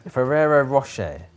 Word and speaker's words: Ferrero Rocher: Ferrero. Yes, Ferrero Rocher, not Ferrero Rocher. As some people pronounce. – Because that's actually, so Ferrero is the surname of the person who created the company, and Rocher is the French for rock or boulder.